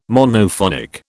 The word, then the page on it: Monophonic: British English